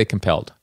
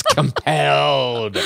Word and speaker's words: compelled: The emphasis has to be on the second syllable. You can't say compelled. – I just did. It worked fine. – It's compelled.